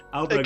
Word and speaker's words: Ahlberg: Is that how it's actually pronounced? – I believe so. It's spelled A-H-L-B-E-R-G. – Oh, I did not know it had an H in it.